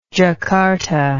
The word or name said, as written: jakarta